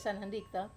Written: sanandika

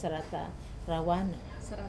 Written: sarata rawana

